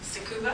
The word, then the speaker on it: Tsukuba: And he had completed his PhD on the London Greenbelt at the University of Tsukuba.